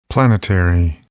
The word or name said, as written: planetary